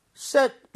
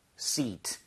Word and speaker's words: seat: So, set 이게 아니라 seat, seat 해보세요.